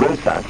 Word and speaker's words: Rosas: It's Caroline, A321 Rosas, which is spelled R-O-S-A-S, Rosas Girona in Spain.